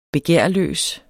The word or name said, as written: begærløs